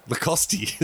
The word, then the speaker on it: Lacoste: Lacoste, is that how we pronounce it? That's how it's pronounced.